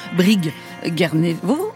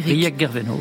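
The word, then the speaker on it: Brieg Guerveno: Alors je pense que je le prononce pas du tout comme il faut, puisque heureusement Eric Marchand vient de me dire que ça ne se prononce pas du tout Brig Guernevo, mais euh, d'ailleurs, mais euh, plutôt Briec, c'est ça, hein, Eric Briec Gerveno, oui.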